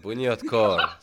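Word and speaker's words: Bunyodkor: I was going to say Bunny Koder. Is that Bunyot the name of the team? – There we go. Bunyot Kor.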